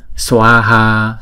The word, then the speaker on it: Swaha: Swaha sờ quờ ha. Swaha swaha swaha. Hai âm và mỗi âm là hai nhịp. Swaha.